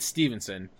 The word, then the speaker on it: Stevenson: Everyone calls my last name, it's Stevenson, but people say Stephenson or just Steven, and then when they spell it, it's never with a PH.